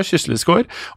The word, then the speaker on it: Kysslis gård: uttaler jeg det riktig? Kysslis? – Jeg tror det. – Ja, Kysslis gård, og familien på Storhaug gård.